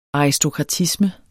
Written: aristokratisme

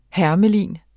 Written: hermelin

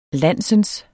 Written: landsens

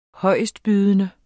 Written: højestbydende

